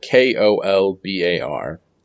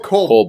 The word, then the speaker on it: Kolbar: But uh, I, I watched with subtitles, and it said K O L B A R. Oh, Kolbar.